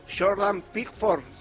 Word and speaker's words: Jordan Pickford: Jordan Pickford será el arquero.